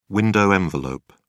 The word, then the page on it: window envelope: British English